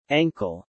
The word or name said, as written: ankle